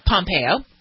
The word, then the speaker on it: Pompeo: Yeah, and they already had, I guess, CIA Director Mike Pompeo, or is it Pompeo? – Pompeo. – Pompeo, okay.